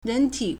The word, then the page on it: 人体: réntǐ